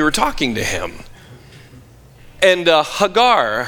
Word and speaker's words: Hagar: Her Hebrew name is pronounced Hagar. We would say Hagar, the same way we would say Abram when his Hebrew name is really Avram, and Sarai, and we'd say Sarah.